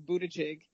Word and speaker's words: Buttigieg: But yes, it's Buttigieg. Ew. Not Butt Geek, Butt Cheek, or whatever.